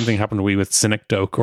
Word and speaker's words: synecdoche: Synecdoche is how to pronounce that one.